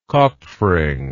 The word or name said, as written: kopfring